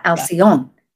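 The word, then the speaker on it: Alcyone: But to have Ascalon, right. Am I saying that right? I have trouble with Alcyon. – Yeah, Alcyon. Yeah, it was the wild Alcyon.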